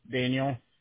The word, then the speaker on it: Daniel: Daniel. – Daniel. So it's spelled as D-A-N-I-E-L.